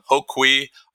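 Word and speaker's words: Ho Quee: Well, I, I want to first thank you for pronouncing it correctly. I feel like everybody that I, I the first when I meet people, the first thing that I, I kind of uh, have to do is give them uh, provide them with an education around how to pronounce my last name. Um, so it's uh, Hoquee.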